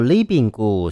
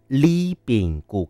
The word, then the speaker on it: Ribingu: 他们叫做 Li Bing Gu，虽然是发音 Li Bing Gu，可是它的第一个英文字母叫做 R 哦，R-I-B-I-N-G-U，客语的双人组合。